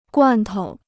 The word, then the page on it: 罐头: guàn tou